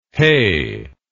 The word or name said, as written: heh